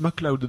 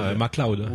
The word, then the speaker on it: McLeod: Sauf que McLeod, ça se prononce MacLeod dans le film. – MacLeod.